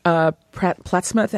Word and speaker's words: Plattsmouth: Marilyn, you're calling from Plattsmouth. Am I pronouncing this properly? Nebraska. – Yes, ma'am.